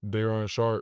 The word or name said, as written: Day'Ron Sharpe